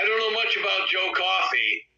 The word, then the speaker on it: Coffey: The men's match was Trick Williams against Joe Coffey. Pronounced like the drink, but spelled differently.